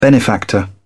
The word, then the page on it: benefactor: British English